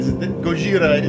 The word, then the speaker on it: Gojira: Yes, Gojira is how you pronounce it. – Like, that's Japanese. – That's what Godzilla is. Godzilla is our pronunciation. Gojira.